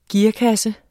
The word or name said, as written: gearkasse